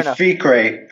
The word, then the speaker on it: Fikre: Okay, it's actually Fikre, but okay.